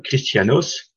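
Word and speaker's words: Christianos (Χριστιανός): Alors, je ne vais pas vous lire le nom en grec, mais c'est prononcé Christianos, veut dire littéralement petit Christ.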